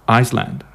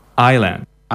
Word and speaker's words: island: But island, like isla in Castellano or illa, you know, in Catalan, is often mispronounced and people say Iceland, yeah? But no, it's island.